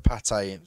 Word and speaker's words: pâté: The pâté, is that how it's pronounced? – Yes.